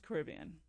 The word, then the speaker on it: Caribbean: And do you pronounce it Caribbean or Caribbean? – It is pronounced Caribbean.